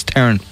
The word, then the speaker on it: Theron: And Charlize Theron. Apparently we've been calling her Theron, and it's wrong. For all these years, it's Theron.